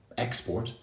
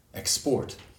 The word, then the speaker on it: export: Also, for words that can be used as both verbs and nouns, for example, export, export, the noun has stress on the first syllable, export, and the verb tends to have stress on the second syllable, export. So, for example, uh, UK exports have dropped this year, the noun.